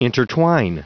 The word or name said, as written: intertwine